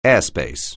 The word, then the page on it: airspace: English